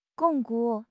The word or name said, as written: 巩固